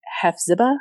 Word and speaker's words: Hephzibah: Wanda Barzee also changes her name to Hephzibah. Is that how you say her name? – Yeah, but really quick, like, but before like this happens, they appear to be this like, you know, really Mormon couple.